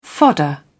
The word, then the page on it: fodder: British English